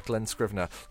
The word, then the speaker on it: Scrivener: how do you want to pronounce his last name? – Glenn Scrivener.